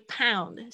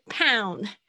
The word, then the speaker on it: pound: 注意一下，这个 pound 是英镑的单位，不要念成胖子。我听到很多同学念成胖子，不是胖子，而是 pound。